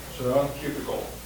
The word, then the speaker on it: Søren Kierkegaard: Søren Kierkegaard.